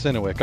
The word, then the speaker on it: Synowiec: Uh, so tonight we got Mac, uh, Don McAlhany, uh, Jim Kleeman, and Rick Synowiec. Did I say your name right? – It's Rich Synowiec. – Rich yes. Synowiec.